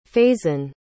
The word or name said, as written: faizan